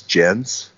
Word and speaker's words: Jens: I don't know how you say it, J-E-N-S, Jens. Mm-hmm. It's like a Scandinavian male name, Jens. – Oh, Jens, yes. – Jens, okay, Jens. Kuhn, K-U-H-N. K-U-H-N, yeah, Kuhn.